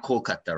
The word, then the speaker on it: Kolkata: So Calcutta is the pronunciation that came into place after the British conquered India. – Okay. – And Kolkata is the native pronunciation of that word. They can be used interchangeably.